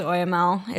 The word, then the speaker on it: oxymel: I've heard it pronounced as OML. It's spelled O-X-Y-M-E-L, mm-hmm.